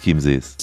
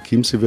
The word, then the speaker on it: Chiemsee: Der Chiemsee wird ja auch im Umgangssprachlichen mit K ausgesprochen. Und diese Schreibweise KY,